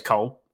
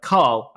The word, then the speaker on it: Cole: So because my name is Cole, I'm not Ryan. Because my name is Cole, they're always like, Carl. And I'm like, no, C-O-L-E. I have to spell it.